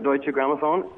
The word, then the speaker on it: Deutsche Grammophon: And it's brought to life by, is it Deutsche Grammophon? Is that how you say it? – That's right, Deutsche Grammophon and Universal.